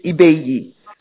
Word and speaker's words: Ibeyi: They're twins, and they perform they pronounce it Ibeyi, right? Because I don't know if it's the Cuban pronunciation.